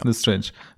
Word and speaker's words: Lestrange: Lestrange wird's doch ausgesprochen. – Lestrange ist der Nachname, ja. Lestrange, ja, genau.